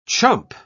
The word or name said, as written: chump